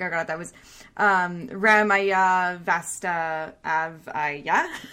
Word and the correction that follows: Ramaiya Vastavaiya: Vastavaiya? Yeah. Oh, Ramaiya Vastavaiya, yeah.